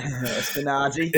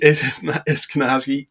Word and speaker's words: Eskinazi: So if you look at Perth at the moment, people might have Bancroft or they might have, uh, is, I can never say his name, Esniaki, is it? Eskinazi. – Eskinazi.